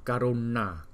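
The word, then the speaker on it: Karuṇā: Rồi chữ N có dấu nặng thì mình phải đọc là N. Karuna, Karuna, Dharani.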